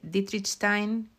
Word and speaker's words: Dietrichstein: Dietrichstein, creo que es la pronunciación correcta en alemán porque se casó con uno de ellos, con Adam.